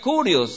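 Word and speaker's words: kurios (κύριος): En griego esta palabra se pronuncia kurios, se escribe kurios.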